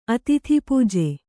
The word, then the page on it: ಅತಿಥಿಪೂಜೆ: Kannada